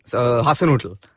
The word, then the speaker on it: Hasanuddin: Like, it would have been very easy for Say his name. – Hasanuddin. – Correct.